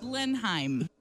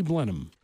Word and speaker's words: Blenheim: Blenheim. – It's actually Blenheim.